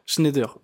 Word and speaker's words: Schneider: Aujourd'hui, donc, tu travailles chez Schneider Electric, c'est la mm. bonne prononciation Schneider. Schneider, ok.